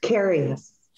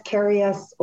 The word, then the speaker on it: Karius: So close. Karius. – Like Karius. – Curious, Karius, or Karius over the goal line.